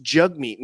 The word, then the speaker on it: Jagmeet: Yeah, that's Jagmeet, spelled Jagmeet, but apparently it's pronounced Jugmeet.